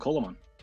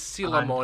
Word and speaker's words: Coelamon: I call him Colamon. – I call him Coelamon. So there we go with three different pronunciations. – It is Seelamon, and... as in coelacanth, the fish.